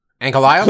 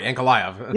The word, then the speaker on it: Ankalaev: Ankalaev? – Please correct me. Yes. – Oh, I wasn't sure you meant. Sorry, Ankalaev.